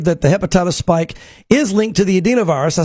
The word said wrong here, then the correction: adenovirus: I said it wrong. The adenovirus, which is uh, like the common cold.